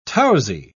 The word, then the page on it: tousy: British English